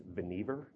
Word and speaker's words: Vannevar: Now, the two things I've learned about Bush since I started researching this, one is his name is actually pronounced Vannevar, and second, he is no relation to the current occupant of 1600 Pennsylvania Avenue.